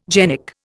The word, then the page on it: genic: Indian